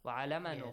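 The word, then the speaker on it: علّم: علم تشديد. Make sure you pronounce it correctly. وعلم yes.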